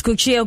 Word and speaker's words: Guccio: Isn't it Guccino Gucci? – What is it? – I mean, I'm sorry, I'm probably mispronouncing it. Isn't it like Guccio Gucci? It's Guccio or Guccio? – It's Guccio Gucci.